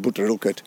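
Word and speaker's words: Brotherilkeld: We pronounce it Butter Ilkert.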